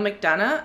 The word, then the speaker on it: McDonough: Frank Gordon, I will say, I didn't put this in there, is played by Neil McDonough, I think his name is, that is how you pronounce his name.